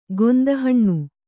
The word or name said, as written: ಗೊಂದಹಣ್ಣು